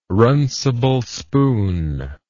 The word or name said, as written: runcible spoon